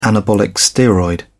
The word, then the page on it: anabolic steroid: British English